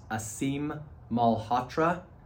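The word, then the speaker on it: Aseem Malhotra: One of the people who I've, it's been interesting to follow, there's a doctor named, I believe it's pronounced Asim Malhotra. It's A-S-E-E-M-M-A-L-H-O-T-R-A.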